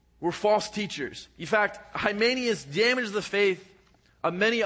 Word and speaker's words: Hymenaeus: So I went into Pastor Lance's office and I said, how do you pronounce this? And he said, Hymenaeus.